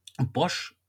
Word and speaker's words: Bosch: Ist aber schwierig, weil ähm, Bosch spricht sich, ist, ist halt die Marke B-O-S-C-H, aber genauso ausgesprochen gibt es im Französischen ein, nennen wir es mal, Schimpfwort für die, Schimpfwort für die Deutschen. Das schreibt sich B-O-C-H. Also S weg, dafür ein E hinten dran.